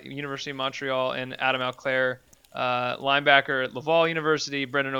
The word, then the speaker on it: Auclair: Number four is Adam Auclair.